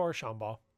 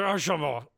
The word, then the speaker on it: Archambault: Yeah, just Archambault. It's just Archambault. Yeah.